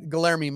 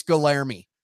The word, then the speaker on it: Guilherme: For a while, we tried Guilherme Marchi, Guilherme. And he'd say, it's Guilherme. Okay.